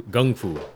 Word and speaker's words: gung fu: It's some guys that do gung fu, because they say it properly.